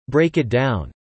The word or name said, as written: Break it down